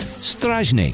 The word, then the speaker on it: strażnik: Like the word for guard, that's strażnik.